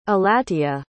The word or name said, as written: alatea